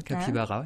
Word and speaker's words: capybara: Après, on a Jana le Capybara, si j'écorche pas le nom. – C'est ça, Capybara.